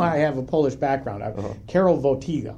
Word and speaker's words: Karol Wojtyła: And when we know this now because the documents are available, but when Karol Wojtyła,